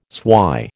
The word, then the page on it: swy: American English